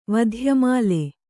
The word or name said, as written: ವಧ್ಯಮಾಲೆ